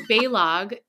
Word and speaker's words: Balogh: my last name is Balogh. It's Hungarian, B A L O G H, but no one can pronounce it because the H is silent. So growing up, I was always like Balau, Balu, Balog.